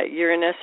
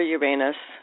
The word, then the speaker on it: Uranus: Uranus or Uranus. It's pronounced two different ways.